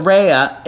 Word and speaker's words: Raya: So Raya, it's pronounced spelled R-A-Y-A.